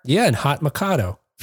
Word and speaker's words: Mikado: Mikado, Hot Mikado, rhymes with baked potato.